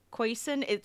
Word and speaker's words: Khoisan: Co- I'm not gonna say it right. Kosin, Kosan, Khoisan. it's K H O I S A N languages, and it's like a ton of different.